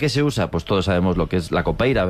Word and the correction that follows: capoeira: ¿verdad? – Capoeira. – Qué mal pronuncio últimamente.